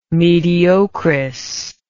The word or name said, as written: mediocris